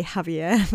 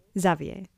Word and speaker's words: Xavier: I feel like he's going I keep wanting to say Javier, but that's like Spanish. How do you say it in French? – Xavier. – Yeah. I feel like Xavier is like yumming and ahhing about whether he should or needs to remove Thomas.